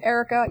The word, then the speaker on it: Erika: If you want, it's Erika, E-R-I-K-A, dash, Andresen, A-N-D-R-E-S-E-N.